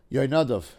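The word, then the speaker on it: Yoynadav (יונדב): Shmo Yundav. Yoynadav, if you want to say that correctly. Ben Shema Achi David, but I think Yundav sounds better.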